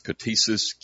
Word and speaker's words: ktisis: Usually I've heard it pronounced ktisis, K-T-I-S-I-S, if you transliterate it into English.